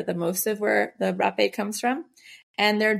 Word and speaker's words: rapé: And the last one is rapé, also pronounced hapé, because it's spelled R-A-P-E. It has an accent over the E, but that looks like a word that, you know, it's not good to type out on the internet.